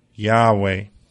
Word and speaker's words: Yahweh: And in the Hebrew, that is pronounced Yahweh. Yahweh is the name of God as revealed to Moses in the burning bush.